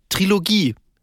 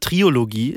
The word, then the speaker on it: Trilogie: Ja, auch ein anderes Wort, was auch häufig falsch gesagt wird, ähm, Trilogie. Ganz viele Menschen sagen, ich auch, mich eingeschlossen früher, Triologie ist auch falsch.